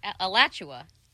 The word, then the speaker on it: Alachua: Alachua.